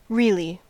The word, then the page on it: really: American English